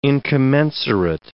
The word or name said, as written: incommensurate